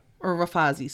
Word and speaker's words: Rafazi: So on the island of Madagascar, there were two siblings of the king, Ramenga and her brother Rafizi. And, or Rafazi, sorry. I think it's a long A.